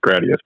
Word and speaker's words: Gradius: I'm going to be the asshole that tells you it's pronounced Gradius, by the way.